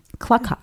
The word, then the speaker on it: Klokov: Is that how it is pronounced? – Spell it. – K-L-O-K-O-V. Klokov. – Klokov? – Klokov. – Klokov. – Klokov. – Klokov. – There we go.